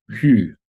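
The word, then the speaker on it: Sjöö: I mean, it actually kind of focuses on, our central totem is the artist Monica Zhu, which is actually pronounced Hu, I believe, but it's spelled S-J-O-O.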